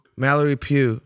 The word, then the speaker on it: Mallory Pugh: Mallory Pugh.